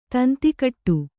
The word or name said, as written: ತಂತಿಕೊಡು